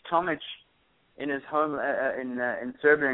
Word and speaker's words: Tomic: If Eastern Europe, it's Tomic. Obviously, yeah. – with the Serbian Croatian pronunciation of the IC at the end, so.